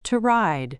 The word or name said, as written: to ride